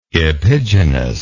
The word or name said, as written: epigenous